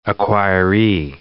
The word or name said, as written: acquiree